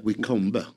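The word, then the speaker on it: Wycombe: Då har ju Queens Park Rangers fått in Heter de Wickham? Jag vet inte hur det uttalas riktigt. W-I Wickombe. Ja, just det.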